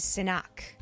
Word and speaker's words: Sinach: is Sinak. S-I-N-A-C-H, is how you pronounce it or spell it.